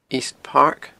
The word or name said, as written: East Park